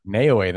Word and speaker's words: Naoya: NAOYA, thank you.